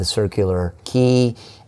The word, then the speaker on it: Circular Quay: and then we went down to the Circular Quay, and you got the pronunciation right.